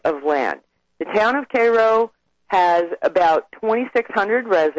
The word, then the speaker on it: Cairo: And the Army Corps of Engineers blew up the levee to save a little town called Cairo. Everyone here calls it Cairo, but it's spelled Cairo, like Cairo, Egypt.